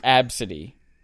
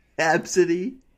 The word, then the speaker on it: Abcde: The story of the girl whose name is A B C D E, pronounced Absidy. Absidy and the uh, United Airlines hostess who laughed at her and made fun of her.